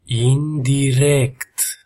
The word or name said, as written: indirect